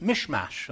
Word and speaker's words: Michmash: Actually, that's pronounced Mishmash, and that's where that, it's a Mishmash. That's where it comes from.